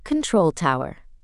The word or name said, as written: control tower